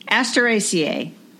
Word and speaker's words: Asteraceae: How do you say that even? Asteraceae? – Asteraceae. – Ooh, I did it right.